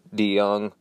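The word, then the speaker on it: DeJong: So, yeah, so my last name is DeYoung, and, I understand that it's, like, hard to pronounce because it's D-E-J-O-N-G, and it's a Dutch name,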